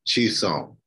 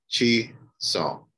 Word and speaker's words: Chisom: Chisom, but. – Okay, say it again. – Chisom.